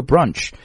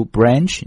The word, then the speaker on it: branch: 有将近 40,000家分支机构 ,40,000 branches, 啊，注意这个 branch, 你既可以读 branch, 你可以读 branch, 都行啊，读 branch 是英式的发音 ,branch 呢是美式的发音。